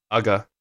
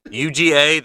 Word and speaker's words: Uga: I guess it's pronounced Uga? – Uga. – Uga? Oh, okay. I mean, you'd think there'd be two G's there. You'd think there'd be two G's there. UGA, that's Uga.